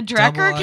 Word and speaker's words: Drekker: The Drekker cans are, I hope I'm pronouncing that right. – D-R-E-K-K-E-R.